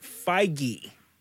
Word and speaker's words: Feige: Hey, Tony Stark or Robert Downey Jr. pronounces it Feige.